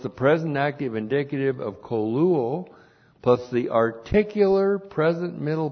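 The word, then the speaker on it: koluo (κωλύω): K long O, L U long O, koluo.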